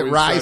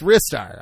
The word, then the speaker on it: Ristar: Some people say Ristar, some people say Rystar. – Except for one guy. – And one guy was like, well, it comes from like a Japanese translation of this or whatever, so it's actually pronounced Rystar. Rystar. And everyone's like, no. – That guy's wrong. it's Ristar. I'm pretty sure it's Ristar. – Either Ristar or Rystar. Rye like the bread. – Rye. Okay. Well, rye like the bread is R-Y. – Yes. – R-Y-E.